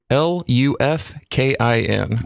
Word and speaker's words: Lufkin: L U F K I N. Wow, I Lufkin.